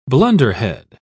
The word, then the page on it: blunderhead: British English